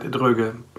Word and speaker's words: Droege: You were pretty right, Droege.